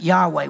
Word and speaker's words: Yahweh: It's YHWH. We pronounce it Yahweh. We're not sure how to pronounce it, but that's what we say.